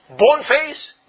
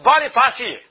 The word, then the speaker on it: Boniface: The brother over in Uganda whose name was Boneface, if you read the name, it's spelled with a bone face. But it's pronounced as Boniface.